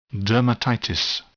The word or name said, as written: dermatitis